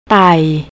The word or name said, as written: tai